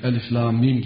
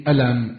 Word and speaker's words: Elif Lam Mim: Bunlar mesela Elif Lam Mim, Elem, Elim, Ulim diye okunmaz. Bu nasıl okunur? Elif, Lam, Mim diye okunur.